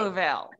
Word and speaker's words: Louisville: She pronounces Louisville the right way. – Louisville.